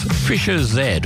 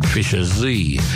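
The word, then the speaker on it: Fischer-Z: Moving now to a Brit band, pronounced in the English way as Fisher Z, although some American DJs refuse to change from their habitual Fisher Z.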